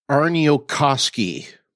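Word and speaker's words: Aarniokoski: But, and I'll get to it in a second, directed by Douglas, and I got it wrong during the short track, Douglas Arniokoski is, I believe, how you say his name.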